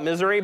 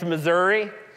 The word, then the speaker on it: Missouri: Some of you call it misery, but it's Missouri. That's the correct pronunciation.